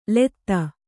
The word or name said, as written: ಲೂತಿ